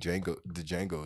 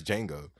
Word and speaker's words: Django: I was sitting back thinking today, like, I think Django is my favorite. Why did I say Django? It's Django. I said it right, and then I fucking second-guessed myself.